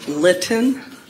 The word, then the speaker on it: Lytton: Diane Litton. – Can you spell that? – L-Y-T-T-O-N.